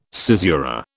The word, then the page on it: caesura: British English